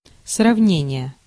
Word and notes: comparison: American English

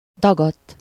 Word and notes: dagadt: /ˈdɒɡɒtː/

